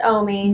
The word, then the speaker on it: Omi: I pronounce it differently. I say Omi. – But you just call her Omi? – Sometimes I call her. – Did I say Omi? – Yeah. – Oh, Jesus. – Yes, yeah, you're like. – It is Omi.